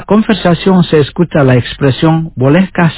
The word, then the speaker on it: Bolehkah: Bolehkah significa puede o puedo. Lo pronunciaré lentamente. Puedes repetir después de mí. Bolehkah.